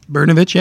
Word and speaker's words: Brnovich: Did I pronounce his name correctly? – Brnovich, yeah. I think you said it.